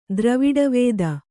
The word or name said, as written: ದ್ರವಿಡವೇದ